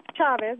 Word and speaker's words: Chavez: And by the way, it is pronounced Chavez.